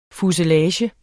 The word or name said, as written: fuselage